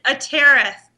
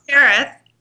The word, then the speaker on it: Atereth: It's Atereth. And Atereth. – I had a fabulous DJ tell me how everybody can remember it. Ready? – Uh-huh. – Atereth, like tearing up the charts.